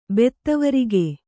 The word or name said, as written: ಬೆತ್ತವರಿಗೆ